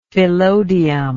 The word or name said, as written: phyllodium